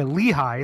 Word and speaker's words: Lehigh: Is that how it's pronounced? – Yeah, Lehigh. It's not a weird word.